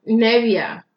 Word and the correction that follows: Niebla: It's spelled N-I-E-B. Oh, there's an L. Niebla. Niebla.